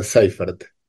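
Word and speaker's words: Seyfert: aclarar que era, bueno, constaté que era Seifert, como se Seifert. – pronuncia.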